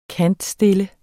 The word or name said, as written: kantstille